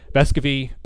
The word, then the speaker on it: Vescovi: I expect, though, we'll see a heavy dose of Vescovi excuse me, I'll say his name right, Bescovy,